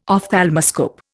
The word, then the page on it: ophthalmoscope: Indian